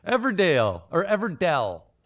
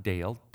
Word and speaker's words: Everdell: Everdell. It's not Dale. Dell. Everdell.